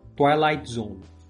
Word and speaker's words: Twilight Zone: Twi Twilight, Twilight Zone, isso a pronúncia.